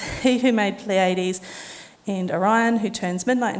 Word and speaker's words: Pleiades: In Greek language, now my Tereo is a lot better than my Greek, but it's pronounced Pleiades.